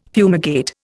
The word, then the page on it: fumigate: Indian